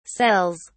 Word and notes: cells: British English

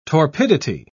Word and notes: torpidity: British English

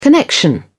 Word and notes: connection: British English

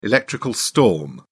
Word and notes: electrical storm: British English

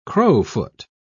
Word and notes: crowfoot: British English

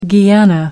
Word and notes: guyana: American English

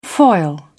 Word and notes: foil: British English

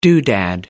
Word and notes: doodad: British English

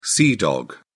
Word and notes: sea dog: British English

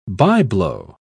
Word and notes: by-blow: British English

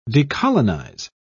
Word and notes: decolonise: British English

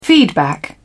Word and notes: feedback: British English

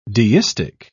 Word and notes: deistic: British English

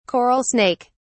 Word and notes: coral snake: British English

